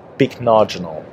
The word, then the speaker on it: Pycnogenol: So it's P-Y-C-N-O-G-E-N-O-L, pycnogenol.